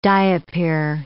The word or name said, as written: diapir